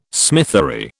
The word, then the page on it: smithery: British English